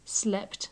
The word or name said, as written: slept